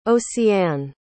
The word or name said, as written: oceane